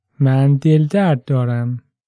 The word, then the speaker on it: من دل درد دارم: If it's your stomach that's hurting, you can say من دل درد دارم. Let's break it down by syllable and hear it one more time. من دل درد دارم.